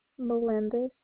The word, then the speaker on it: Melendez: L-E-S-L-I-E? – Yeah. – And your last name? – Melendez. – Spell it for me. – M-E-L-E-N-D-E-Z. – M-E-L-E-N-D-E-Z.